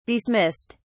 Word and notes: dismissed: British English

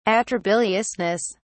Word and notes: atrabiliousness: British English

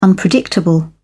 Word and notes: unpredictable: British English

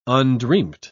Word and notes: undreamt: British English

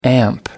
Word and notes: ampere: British English

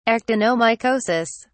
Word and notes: actinomycosis: British English

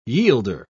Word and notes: yielder: British English

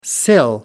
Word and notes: sill: British English

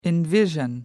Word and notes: envision: British English

